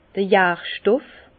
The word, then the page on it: d'r Jarstoff: Alsatian; Bas-Rhin; Herrlisheim